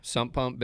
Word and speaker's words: sump pump: And there's sub pumps underneath houses. – That's Right. – Did I say it right? – Yeah. Sump pump, basin, French drain.